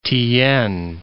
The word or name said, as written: tyiyn